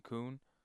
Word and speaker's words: Kuhn: Keegan Kuhn. And yes, it's pronounced like that,